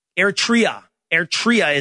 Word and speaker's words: Eritrea: Eritrea is how it's pronounced.